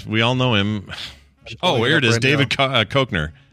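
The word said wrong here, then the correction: Koechner: Or Cochner, however you say his name. – Keckner, David Keckner from Anchorman, and Get Smart, all that stuff.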